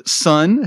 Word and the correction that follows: San: who's the princess. – Yes. – San. Thank you.